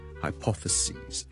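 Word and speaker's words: hypotheses: 仲有其他字都系咁嘅，譬如 hypothesis，一个假设 hypothesis， hypothesis， hypothesis， hypothesis，亦都系来自希腊文嘅一个字。 So hypothesis， hypothesis plural 咧，我哋系 s i s 转咗做 s e s，就变咗 hypothesis， hypothesis， hypothesis。记住个发音，OK？由 sis 转咗做 sees，你当好似系 c s e a 或 s e e 嗰个 c，跟住再加多 s。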